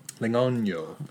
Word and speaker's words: Legnano: It's spelled Legnano, but you're like the actual pronunciation, you're supposed to like flip the N and the G.